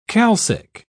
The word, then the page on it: calcic: British English